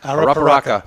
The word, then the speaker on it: Arapiraca: But it's a Brazilian, and this, when you see the word, it's a little deceiving, but it sounds just like it's spelled. This is the Arapiraca. – Arapiraca.